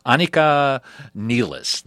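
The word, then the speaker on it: Anika Nilles: Anika, I'm going to mispronounce her name probably, Anika Nielis, that's how you say her name.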